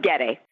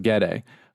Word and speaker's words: Gade: One of them is your use of windows, such as in your 2013 House of, and here's where I'm going to mess up pronunciation, House of Gade? Gade? – House of Gade. – House of Gade.